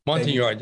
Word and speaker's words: Montagnard: So, as RT uh, Python was being uh, headed into the uh, target, uh, RT Intruder with five green berets and hopefully I pronounce this correctly, fry, uh, five brew Montagnard Munton Yard, yes, sir.